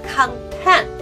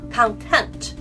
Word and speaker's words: content: 另外要注意一下 content 这个词发音重音位置不一样的时候，它意思也不一样。 content 是表示说我是满足的，但是如果我说呃 content，那是另外一个意思了，表示书的目录，OK？